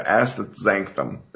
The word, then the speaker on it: astaxanthin: Briefly tell us about, I can't even pronounce it right, the astaxanthin.